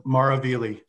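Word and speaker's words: Maravigli: That's Mara Vili, silent G, Your Honor.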